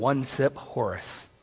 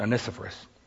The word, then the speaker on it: Onesiphorus: Good old one-sip Horace. Or as we pronounce it, Onesiphorus. Onesiphorus, there we go.